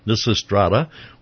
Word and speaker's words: Lysistrata: So what we're doing is we are talking to 3 of the people involved in Lysistrata, which is a play that, did I say it right? – Yes, beautiful.